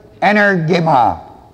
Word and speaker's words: energema: And that's the word that's spelled M-A-A-B-D-A-N-U-T-H-A. The Greek word is the word energema. Energema. It's spelled E-N-E-R-G. Long E-M-A.